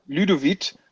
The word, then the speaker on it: Ludovit: Aber es ist die Barca-Leihgabe, die, wie wird er ausgesprochen? – Ludovit. – Ludovit Genau.